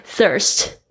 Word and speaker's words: thirst: Thirst. 要注意一下你们 th 的发音哦， thirst 这边是当动词 thirst，那其实我们常常知道的一个字其实就是 thirsty，它是后面加上 y 变成口渴的。